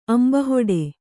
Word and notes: ಅಂಬಲಹೊಡೆ: Kannada

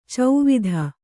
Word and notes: ಚೌವಿಧ: kannada

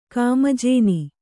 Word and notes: ಕಾಮಜೇನಿ: Kannada

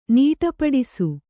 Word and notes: ನಡಪಾಡಿಸು: naḍapāḍisu

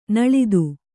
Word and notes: ನುಲಿತ: Kannada

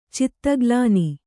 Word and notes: ಚಿತ್ತಗ್ಲಾನಿ: Kannada

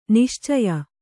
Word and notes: ನಿಶ್ಚಯ: niścaya

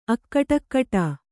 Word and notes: ಅಕಟಕಟಾ: kannada

